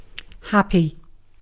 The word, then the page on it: happy: British English